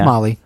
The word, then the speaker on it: Mali: Yeah, and then hopefully I'm saying it right, because it could be like Mali. Mali? – No, it's Mali. It's Mali.